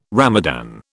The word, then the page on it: Ramadan: British English